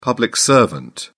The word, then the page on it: public servant: British English